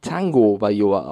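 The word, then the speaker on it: Tagovailoa: Er wird zwar Tagovaioa geschrieben, aber man spricht ihn Tango Vaioa aus.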